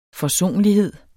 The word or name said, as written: forsonlighed